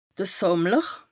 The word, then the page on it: Sàmmler: Bas Rhin